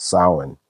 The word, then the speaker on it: Samhain: Halloween's origins date back to the ancient Celtic festival of Samhain, and that's pronounced Samhain.